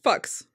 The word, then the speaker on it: Fuchs: And my favorite, it's spelt F-U-C-H-S. – Fucks. – Fucks the selfish.